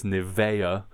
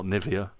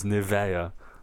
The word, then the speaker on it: Nivea: I've just remembered it's Nivea here in Germany, not Nivea. For our confused German listeners, Nivea.